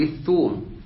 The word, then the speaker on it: الثوم: هو هكذا بالضم الثوم عربيته الثوم ماشي ليس الثوم ماشي